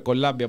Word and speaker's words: Columbia: Και από κάτω, ο κύριος Διαματάρης, γιατί δεν είναι κανένας απατεώνας ή κανένας ψεύτης, βάζει αντίγραφο του πτυχίου που πήρε από το Columbia, Columbia, όπως προφέρεται αυτό, University in the City of New York.